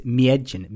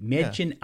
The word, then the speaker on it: Mädchen: Well, with the little thing over the A there, it's Miedjin. – Miedjin yeah.